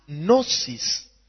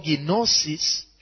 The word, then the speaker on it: gnosis: So we're going to look at gnosis and ginosko. Praise the Lord. Gnosis or ginosis, it can be pronounced as gnosis or ginosis. Praise the Lord. That is G-N-O-I-S-I-S. G-N-O-S-I-S. Gnosis. And then ginosko is G-N-O-S-K-O.